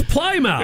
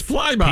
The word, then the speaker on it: Plymouth: And why don't they pronounce it like I did as a kid when I first read the word Plymouth? ah, it's Plymouth. It's Plymouth. P L Y M O U T H. Plymouth.